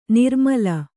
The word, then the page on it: ನಿರ್ಮಲೆ: kannada